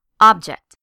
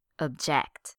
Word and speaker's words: object: With the noun, the stress is placed on the first syllable, object. With the verb, the stress is placed on the second syllable, object.